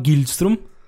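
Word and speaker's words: Gillström: So the kiosk man, or Voldemort Gilbag. No, his name is, did I pronounce that right? His name is Voldemort Gilström.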